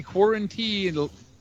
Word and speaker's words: Quarantillo: Gavin Tucker versus Billy Quarantillo, I think is actually how he pronounces it.